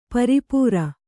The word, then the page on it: ಪರಿಪೂರ: pari pūra